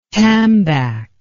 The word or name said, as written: tambac